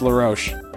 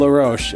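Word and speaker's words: La Roche: It's LaRoche. It's LA space ROCHE. Phonetically, it's LaRoche.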